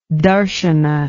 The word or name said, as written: darshana